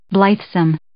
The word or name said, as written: blithesome